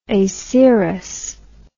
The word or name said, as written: acerous